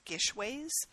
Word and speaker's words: GISHWHES: It stands for, so it's spelled G-I-S-H-W-H-E-S, and I guess it's Gishways.